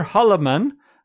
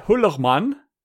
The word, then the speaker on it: Hullermann: And among them, there was specifically one Reverend Peter Hallermann, which is not how you pronounce it, Annika, because he's German, so I think it's more like Peter Hullermann.